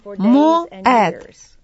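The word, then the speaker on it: моэд (מועד): Слово «знамение» на иврите — это «моэд». Оно произносится как «моэд».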